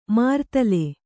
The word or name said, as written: ಮರ್ದಲ